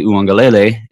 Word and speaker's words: Uiagalelei: and then DJ Uiagalelei in 2021.